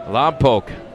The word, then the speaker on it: Lompoc: He was drafted out of high school out of Lompoc, California, which I learned how to pronounce from the former Rainiers hitting coach, Roy Howell, who was from Lompoc.